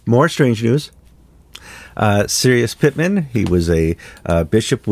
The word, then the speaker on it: Cyrus: Yes, you've given the Persian pronunciation of Cyrus, Sirius. His name is Cyrus Pittman. But in Iran, you say Sirius.